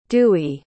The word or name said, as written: dewey